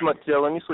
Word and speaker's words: Matyabaniso: It's Matiawaniso Nyirenda.